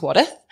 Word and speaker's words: Wadworth: Spelt Wadworth, but it's pronounced Wadith.